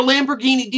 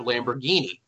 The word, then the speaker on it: Lamborghini: Now, DuckDuckGo tells me, here are four tips that should help you perfect your pronunciation of Lamborghini. Break down the sounds. Lamb. Ba, gee, and G-H-E-E, knee, N-E-E.